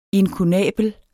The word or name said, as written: inkunabel